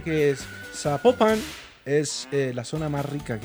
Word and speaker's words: Zapopan: Oiga, nos escriben, nos escriben los oyentes y que no se, no se pronuncia Zapopan, sino que se pronuncia Zapopan. – Zapopan.